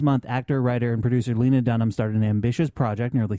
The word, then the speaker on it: Lena Dunham: Lena Dunham? Is that how you pronounce it? – It's Lena Dunham, yeah.